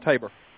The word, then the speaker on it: Tabor: Well, speaking of the secondary, that Marlon Humphrey, help me with the pronunciation. Is it Tabor? – Yeah. Jalen Tabor.